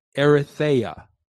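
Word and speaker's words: erythea (ἐριθεία): Now, the phrase selfish ambition in James chapter 3, verse 14 is the Greek word erythea. Erythea. Erythea is spelled E-R-I-T-H-E-I-A.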